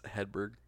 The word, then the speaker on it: Hedberg: Well, how would you say it? – It's Hedberg.